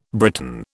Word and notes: Briton: British English

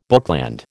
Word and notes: bookland: American English

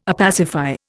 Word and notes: opacify: Indian

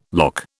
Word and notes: lough: British English

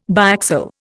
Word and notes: biaxial: Indian

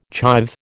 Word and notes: chive: American English

